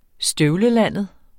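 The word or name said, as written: STØVLELANDET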